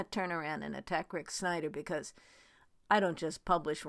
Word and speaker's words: Snyder: You know, if you want to write a poem about Snyder, it rhymes with spider.